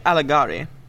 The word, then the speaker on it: Alighieri: Dante Alighieri.